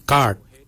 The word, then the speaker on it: gaad: Yimang is spelled Y-I-M-O-O-N-G, yimang. Yimang is forehead. The next word is head. For head, we say kart. Kart. Kart is spelled G-A-A-D, kart. The next word is hair. For hair, we say jawin. Jawin. Jawin is spelled D-J-O-W-I-N-Y.